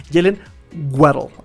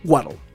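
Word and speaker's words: Jaylen Waddle: Jalen Waddle, así se pronuncia, Waddle. Yo ya lo, ya lo comprobé con Oxford.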